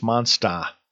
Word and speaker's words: Monster: "It's pronounced Monsta," said Dave, our tour guide. "In Boston, there are only 25 letters in the alphabet. There are no R's."